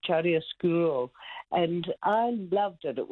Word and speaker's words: chiaroscuro: I don't know if I pronounce it properly, but Chiaroscuro. It was what I actually phoned up for. – Oh, look, your pronunciation's beautiful.